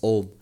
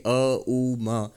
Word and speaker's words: Om: If you combine them, it becomes Om. Many people didn't know that, and then they know it, oh, and now I know it's actually a, u, ma, and so I should pronounce it as aum. No, don't.